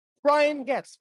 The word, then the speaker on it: Brian Goetz: I'm standing right next to Brian Goetz.